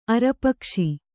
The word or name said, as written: ಅರಪಕ್ಷಿ